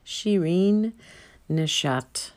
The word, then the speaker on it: Shirin Neshat: And when I looked her up, it came up on the National Museum of Women's Arts website with a way to say her name. Shirin Nishat. It's spelled S-H-I-R-I-N. N-E-S-H-A-T. She was born in 1957.